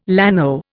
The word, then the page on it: llano: Indian